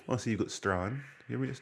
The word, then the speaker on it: Strachan: Is there a silent J in there? S T R A C H A N. So everyone goes, "Oh, Strachan." Oh, Strachan. but it says Strachan on the sign. Yeah, I know it says Strachan on the sign, but it's pronounced Strachan.